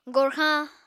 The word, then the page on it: gorxañ: Apatani